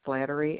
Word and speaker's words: Slattery: First name is Lori, L-O-R-I. Last name is Slattery, S-L-A-T-T-E-R-Y.